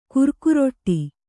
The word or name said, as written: ಗಿರಿಕಾರೂಢ